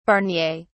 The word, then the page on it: barnier: British English